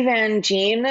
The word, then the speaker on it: Van Gyn: I'm Lindsay Van Gyn on, on LinkedIn.